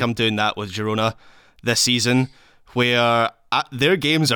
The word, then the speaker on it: Girona: I looked it up. Girona is a Catalan word. So it's Je. So there we go. Apologies for ever calling it anything other than Girona.